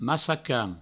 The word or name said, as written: massacan